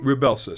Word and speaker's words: Rybelsus: Rybelsus. So Rybelsus.